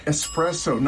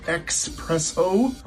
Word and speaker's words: espresso: Espresso, not expresso.